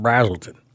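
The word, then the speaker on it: Braselton: I got to say it right. And Brazelton. – And Brazelton. Yeah, you say that right now. – I did just say it right, didn't I? – Yeah.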